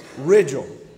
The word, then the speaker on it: Ridgdale: I do pronounce your last name right, don't I? – No. – Okay. All right. How do you pronounce it, brother? – Ridgdale. – Okay. I pronounce it like it's spelled. He pronounces it like he wants to.